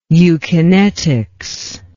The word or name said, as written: eukinetics